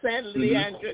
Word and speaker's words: San Leandro: San Leandro, mm-hmm.